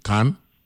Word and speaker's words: Cannes: we get and, and, at some point, you know, the goal is to get to how you pronounce it, Can or or Kane? Can. Can yeah. Can C A N N E S right? – Yeah.